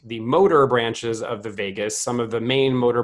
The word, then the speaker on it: vagus: If you're on this call and you don't really know that, it's spelled V-A-G-U-S, not like Las Vegas, but vagus in Latin, which means wanderer.